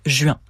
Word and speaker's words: juin: Efforçons-nous de rétablir les bonnes voyelles, ju-un, donc juin.